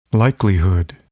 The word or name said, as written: likelihood